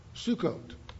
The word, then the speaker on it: Sukkot: The correct, if you want to know how to pronounce Sukkot, it's phonetically laid out as Su, S-U-E, hyphen, C-O-A-T, coat, Sukkot. That's the correct pronunciation of the word.